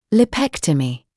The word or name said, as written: lipectomy